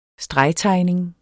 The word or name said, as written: stregtegning